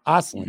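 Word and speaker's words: Östlund: Um, the Sabres again picked at sixteen, did not move up or down, and they took uh Noah Ostlund. Ostlund, mm-hmm. I said that right, two for yep. two, baby. You yeah. nailed it.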